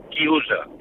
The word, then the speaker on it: chiusa: Y un momento, y quiero decirle a Fernando que en italiano se dice chiusa.